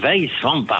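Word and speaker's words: Weiswampach: Si on le dit avec l'accent luxembourgeois, je devrais vous dire Moyen pour vous dire bonjour. Et je devrais vous dire Weisswamper.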